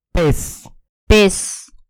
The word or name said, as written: pez